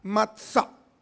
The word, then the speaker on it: matzá: Matzá, así se pronuncia, matzá.